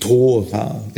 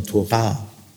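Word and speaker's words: Tora (תורה): Ton auf der zweiten Silbe kennt ihr ja, wenn ihr mich schon ein paar Mal gehört habt. Gell? Nicht Bara, gell? nicht Tora, gell? sondern Tora.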